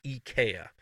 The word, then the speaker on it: IKEA: The proper pronunciation of IKEA is actually IKEA.